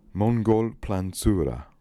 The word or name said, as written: Mongolplanzura